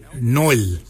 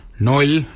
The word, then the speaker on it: nyooly: we say Noil. Noil is spelled N-Y-O-O-L-Y. Noil.